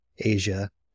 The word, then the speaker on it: Aza: And because at the time, those times, they were very distinct, as I think it was Aza in the video points out. – Asia. It is spelled A-Z-A, which is confusing.